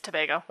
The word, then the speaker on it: Tobago: It's Tobago. – Okay, Tobago. Sorry.